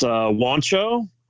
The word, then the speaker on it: Juancho: How do you say his first name? – It's Juancho Hernan Gomez.